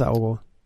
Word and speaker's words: Aogo: Dennis Aogo, spreche ich das richtig aus? – Ja, genau, Dennis Aogo.